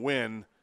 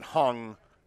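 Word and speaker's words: Huynh: Instead of saying Win, he said Hung because of the way it's spelled, although there's no G in it, but it's H-U-Y-N-H is how you spell it.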